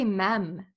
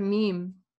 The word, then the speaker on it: meme: I used to say mem instead of meme.